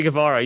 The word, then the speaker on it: Guevara: What they're doing today, you know, the Sammy Guevara, how do you say his name? – Yeah, Sammy Guevara.